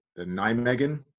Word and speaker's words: Nijmegen: So the NIMEGAN, and it's not spelled how it's pronounced, which is horrible for a dyslexic, NIJMEGEN.